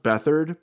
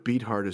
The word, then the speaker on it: Beathard: It's pronounced Beathard, but Beathard is just way cooler.